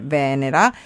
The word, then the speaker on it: Venera: Il nome con cui la chiamo è Venera, l'accento sulla prima sillaba e la finale come una dea o un pianeta che hanno deciso di barare e cambiare le carte sulla tavola.